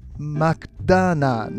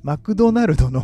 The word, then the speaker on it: マクドナルド: マクダーナの、あ、マクダーナの、あ、すいません、ちょっと発音が良すぎて、うん、マクドナルドの普通に言えってマクドナルドですね。